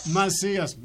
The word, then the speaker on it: Macias: Did I say that right? – Macias. – I'm so sorry about that.